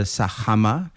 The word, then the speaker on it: Sajama: Alors, j'ai dit, je vais aller grimper le Sajama. C'est comme ça que ça se prononce là-bas.